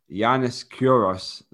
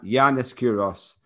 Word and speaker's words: Yiannis Kouros: So he's looking to try and break Yiannis Kouros. Is that how you say it? I think it is. Um, yeah, Jan- Yiannis Kouros, 24 hour world record, which is 188 miles, 188.5 miles, 303 kilometers, which he set in 1997.